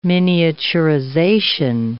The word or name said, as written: miniaturization